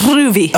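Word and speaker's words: Rouvy: The whole triathlon community seems to love it, but I find it incredibly boring and prefer Ruvi. Is that how you pronounce it? Ruvi? – I think so. – Where it's Ruby.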